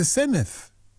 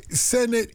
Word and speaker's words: Senedd: Senedd, it's not pronounced like it's written, and you know that.